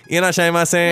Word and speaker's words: いらっしゃいませ: It gets a little nasally, usually. It's something like a, いらっしゃいませ。